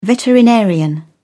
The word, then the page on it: veterinarian: British English